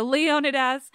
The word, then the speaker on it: Leonidas: Think Thermopylae, Leonidas, Leonidas. I'm going to try to pronounce it the other way because that's how all my guests say it, and it sounds a little bit less like Gerard Butler.